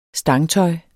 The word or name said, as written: stangsmart